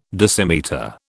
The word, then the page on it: decimetre: British English